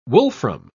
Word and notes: wolfram: British English

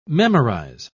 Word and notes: memorise: British English

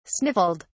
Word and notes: sniffled: British English

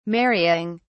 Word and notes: marrying: British English

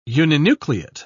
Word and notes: uninucleate: British English

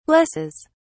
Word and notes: blesses: British English

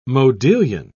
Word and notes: modillion: British English

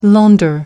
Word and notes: launder: American English

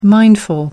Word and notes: mindful: American English

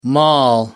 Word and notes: maul: American English

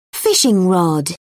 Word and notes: fishing rod: British English

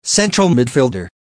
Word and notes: central midfielder: American English

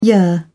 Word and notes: yer: British English

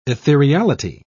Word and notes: ethereality: British English

